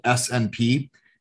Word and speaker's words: SNP: So it's spelled SNP and it's pronounced SNP.